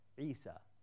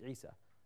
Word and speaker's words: Isa: In the Quran, God calls Jesus Isa, which is in line exactly with the Aramaic. Even, by the way, pronounced to this day by the remnant of the Aramaic people, this is how they say it, Isa.